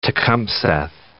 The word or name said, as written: New Tecumseth